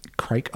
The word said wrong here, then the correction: Kriek: My understanding is it's pronounced Kriek.